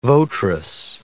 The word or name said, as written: votress